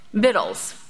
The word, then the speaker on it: victuals: And that word is, it's spelled very funny. I had to look it up, but it's victuals, victuals.